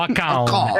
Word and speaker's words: account: Oh, lo pronunci allora, bene però, account.